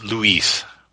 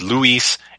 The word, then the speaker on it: Luis: and it's Luis. So it's Luis. In French, it would be Louis, and it would be spelled different. It would be spelled like Louis, but it would drop the S off.